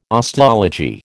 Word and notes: osteology: American English

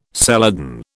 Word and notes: celadon: British English

